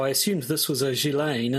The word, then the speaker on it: Ghislaine: But everyone just the only time I've heard people say her name out loud, it's just simply Ghislaine, as though she were Elaine with a G on the front.